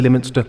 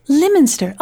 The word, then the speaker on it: Lyminster: Listeners, I'm very excited to introduce to you the Knucker of Lyminster. Am I saying that correctly? – Could be Lyminster. I say Lyminster, but I'm not gonna get in the way of your life. So Lyminster.